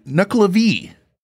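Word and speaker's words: Nuckelavee: This one is called the Nuklavee.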